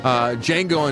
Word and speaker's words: Django: Django Unchained, The D is Silent, Fast and Furious 6, God rest your soul, buddy.